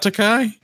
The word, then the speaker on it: Takei: I always want to say Takei. So, I don't know why. – Is it not Takei? – It's Takei. – Oh my God! Takei. I've been saying it wrong this whole time. – I know. – I feel so terrible. – Just think. He's gay. So George Takei, gay. Just use that to keep the rhyme, and you'll be fine.